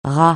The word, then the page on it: ras: francais